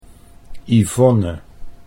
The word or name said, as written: Yvonne